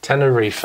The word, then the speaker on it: Tenerife: Tenerife. – That's how they say it there.